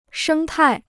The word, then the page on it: 生态: shēng tài